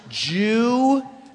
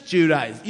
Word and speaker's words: Giudice: I'm like, it's Giudice. You know, Billy Bush email. They're like, how do I say? And then I'm like, it's just Giudice.